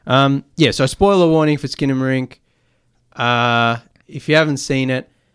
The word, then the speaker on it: Skinamarink: You're back on the Tuesday Review talking all things Skinner Inc.